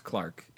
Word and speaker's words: clerk: Because our surname is based off of the word clerk, which is pronounced Clark in the United Britishdom.